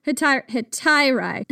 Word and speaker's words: Hetairai: Did I pronounce that right? Hetairai? Hetairai? – Well, that's how I pronounce it. So let's go with it. – Hetairai.